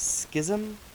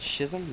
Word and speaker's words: Schism: I started with the song, I don't know how you pronounce it, Schism? I guess that's how you pronounce it. Schism? No, Schism.